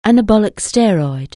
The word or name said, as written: anabolic steroid